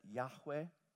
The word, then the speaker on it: Yahweh: Because the word Yahweh is actually consisting of five original consonants in the Hebrew language, W-H-W-H, Yahweh, Y-H-W-H. And then the vowels come in to give us various suggestions. Jehovah was one way of doing it. But scholars really believe that Yahweh is the most likely pronunciation.